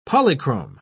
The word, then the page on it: polychrome: British English